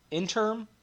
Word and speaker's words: interim: So it's like the Harkonnens got kicked off of Arrakis and there was like a little intern. Not saying that right. Interim family.